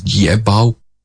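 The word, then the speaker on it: Jiebao (捷豹): It comes out as Jiebao. Jiebao. Jie is to win, and Bao is, in this case, it's a slightly different pronunciation of Bao. In BMW, it's Bao. It has an intonation that goes down and then up, Bao. But here, it's Bao, which means leopard or panther.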